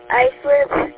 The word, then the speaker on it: Islip: Next up we have East Islip, New York.